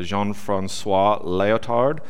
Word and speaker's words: Jean-François Lyotard: And just so you know, the term meta-narrative is brought into prominence by a philosopher, Jean-François Lyotard, who wrote about postmodernism.